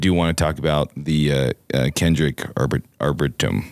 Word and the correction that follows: arboretum: is that right? Did I say that right? Arboretum. – Arboretum. Ar- yeah. Arboretum.